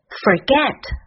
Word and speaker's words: forget: Forget 要注意第一音节的卷舌音，F 在这里的发音是 f，这个音它的关键在于上齿轻触下唇。